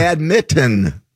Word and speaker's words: badminton: Say badminton. That's how it's pronounced. – Right. Badminton. – But it's spelled badminton. – Exactly. – Oh, so what happened to that other N? – Well, they dropped it.